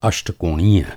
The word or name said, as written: अष्टकोणीय